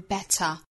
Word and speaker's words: better: 在英音之中呢， better 中间的这个字母 t 的发音是爆破十足的，发成一个 t 的发音， better. 那在美音之中呢，更倾向于发成 flap t 弹舌音 better 当然加上后面的这个 r 的美音的卷舌音，那再这样一组合呢，发成一个 der better.